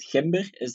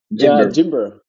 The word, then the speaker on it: GIMBER: Ik zeg altijd GIMBER. Dat is duidelijk fout, want ik hoor u iets anders zeggen dan GIMBER qua uitspraak. – GIMBER. Ja, GIMBER. Ja, maar, dus zelf in het Nederlands moet je eigenlijk GIMBER zeggen.